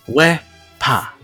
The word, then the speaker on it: wepa: It is pronounced Wepa. W e h p a h.